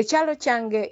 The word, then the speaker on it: Ekyalo Kyange: The Ekyalo Kyange is spelled E K Y A L O K Y A NGE is found on Spotify.